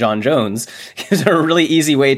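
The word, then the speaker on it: J'onn J'onzz: And when they said John Jones, I was like, that is such a, like... – Yeah, no, that is, it's always pronounced John Jones, and it is spelled that weird way.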